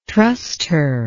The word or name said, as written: trustor